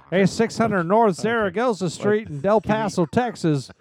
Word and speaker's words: Zaragoza: Texas Nightclub on 600 North Zaragoza Street. – It is. – Why do you have to say it like that? – That's how you say it.